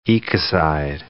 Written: ecocide

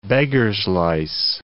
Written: beggar's-lice

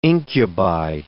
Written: incubi

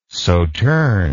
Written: sauterne

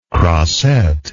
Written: croisette

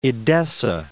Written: Edessa

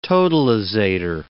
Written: totalizator

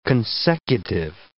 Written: consecutive

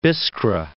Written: Biskra

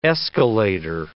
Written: escalator

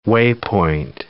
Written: waypoint

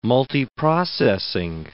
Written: multiprocessing

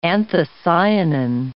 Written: anthocyanin

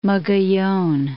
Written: Mogollon Mountains

